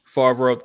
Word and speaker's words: Favreau: One, Favreau. Our MCU man. Favreau.